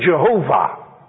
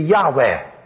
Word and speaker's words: Yahweh: The old timers used to say Jehovah. But now they all agree, all the Jewish and the Christian Bible students all agree that that was a mispronunciation. And they have good reasons to say that. They say it should be Yahweh.